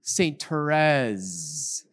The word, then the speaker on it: Therese: Okay, everyone say with me, Saint Therese. Saint Therese, thank you, that's great. Okay, good. It's a short E, not a long E.